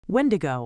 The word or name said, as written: wendigo